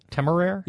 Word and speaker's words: Temeraire: I can't pronounce that, but I assume Temeraire? – In my head, that's how I've always pronounced it. – Temeraire? – Yes.